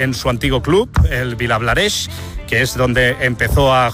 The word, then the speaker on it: Vilablareix: Gracias, desde Vilablareix. Ahora sí que lo he dicho bien.